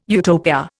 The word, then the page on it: Utopia: Indian